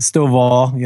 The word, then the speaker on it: Stovall: Stovall, not Stovall? – Yeah. Stovall, yeah.